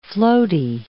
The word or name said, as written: floaty